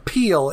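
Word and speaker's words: Peil: Peel. It's P-E-I-L.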